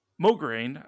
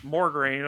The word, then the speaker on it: Mograine: I can't say that. say that correctly. I want to say that there's an R in there. – Morgraine, right? Like I want to say it the wrong way.